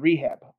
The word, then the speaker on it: R3HAB: We've got Rehab. I'm gonna pronounce it without the stylized version because that's how it goes.